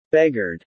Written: beggared

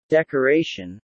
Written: decoration

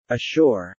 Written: ashore

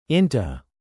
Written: into

